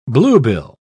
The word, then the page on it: bluebill: British English